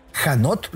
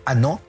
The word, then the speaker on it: Hanot: Gabriel Annaud, para que pueda encontrar bien su nombre, Gabriel Janot, lo pronuncian algunos porque lleva H al principio, H-A-N-O-T, con aquello de que en francés la H es muda, es Gabriel Annaud.